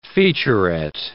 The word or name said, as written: featurette